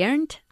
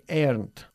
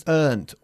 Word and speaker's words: earned: Entonces, all right, I earned a lot of money last year. – Bien, many Spaniards say earned o earned, okay? It's earned, una sola sílaba, earned.